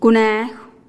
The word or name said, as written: kuneejuh